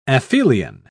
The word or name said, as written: aphelion